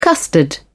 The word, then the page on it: custard: British English